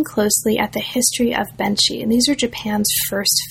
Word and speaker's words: Benshi: focuses mainly on Benshi. Am I pronouncing that correctly? – Yes. – Benshi, great.